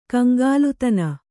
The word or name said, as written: ಕಂಗಾಲುತನ